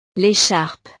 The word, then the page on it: l'echarpe: frances